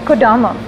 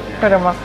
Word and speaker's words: Kodama: So when you wrote Kodama, am I saying it right? – Yeah, I can't pronounce most of your album names. – Kodama.